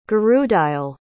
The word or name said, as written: gurudayal